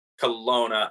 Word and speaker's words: Colonna: Is it Kaluna or Colona? – Colona. I hope I'm saying his name right.